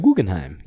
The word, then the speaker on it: Guggenheim: is it the Guggenheim? Is that how you say it? – The Guggenheim.